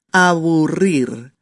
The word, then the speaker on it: aburrir: Empezamos con aburrir. A, B, U, R doble y R sencilla. Aburrir. Un poquito difícil, ¿verdad? La pronunciación de las de estas R's.